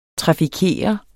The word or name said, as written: trafikere